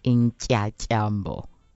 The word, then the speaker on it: Intyatyambo: Intyatyambo.